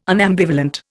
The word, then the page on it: unambivalent: Indian